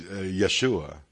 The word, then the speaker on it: Yeshua: His name, even his name, is Yeshua. The proper pronunciation of the name of Jesus, Yeshua Mashiach, that is Jesus the Christ.